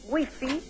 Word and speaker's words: Wi-Fi: W-I-F-I, Wi-Fi, que nosotros, ¿cómo lo pronunciamos? – Wi-Fi. – Pero ya está en el diccionario como Wi-Fi, es decir, que podemos decir Wi-Fi,